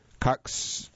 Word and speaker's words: Coccidioides: Soldiers on dusty military bases, prisoners in windswept jails, construction workers pushing new suburbs farther into the deserts have all encountered coccidioides. Let me spell that. C-O-C-C-I-D-I-O-I-D-E-S.